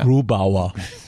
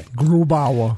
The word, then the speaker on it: Grubauer: Grubauer. – You have to say it like that. I'm sorry. – Grubauer.